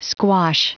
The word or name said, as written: squash